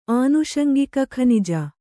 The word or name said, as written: ಆನುಷಂಗಿಕಖನಿಜ